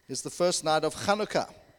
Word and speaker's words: Chanukah: You know, so whenever you see the H, it's a Ch in actual fact. And you see it spelled in so many different ways in phonetically in English. But you're really meant to say Chanukah.